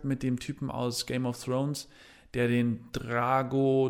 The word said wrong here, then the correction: Drogo: Drogo spielt.